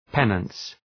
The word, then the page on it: penance: ‘penəns